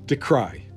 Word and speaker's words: decry: spurring the Chinese embassy to decree the move as interference in international affairs. Decry. Look, let's go with decry. I think that's the proper pronunciation.